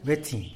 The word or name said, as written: bïtzin